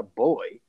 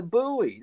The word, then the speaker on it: buoy: It's not a boy. It's a buoy. They've got an O and a U in it.